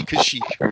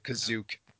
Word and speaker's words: Kashyyyk: It's supposed to be Kashyyyk, but someone in the holiday special pronounces it Kazook.